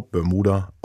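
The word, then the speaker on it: Bermooda: Aber Bermuda geschrieben, also 2 O, Bermuda ausgesprochen.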